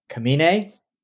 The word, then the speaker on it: Kamine: We have Sara, Kamine, not Carmine, and starring as Mickey.